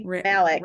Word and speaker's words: Rami Malek: Rami Malek, I don't know how to say his name, unfortunately. – Rami Malek.